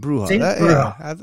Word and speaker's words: bruja: Bruja.